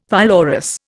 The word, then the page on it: pylorus: Indian